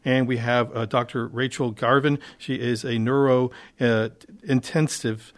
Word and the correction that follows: neurointensivist: doctor, right? Is that right? – Neurointensivist, correct. – Neurointensivist. I missed the syllable there.